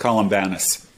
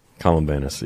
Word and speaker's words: Columbanus: Yeah, I love hearing this story about this this Columbanus. I can't get that right. Which one is it? How do you pronounce his name? – In English, it's usually pronounced Columbanus. – Columbanus, yeah.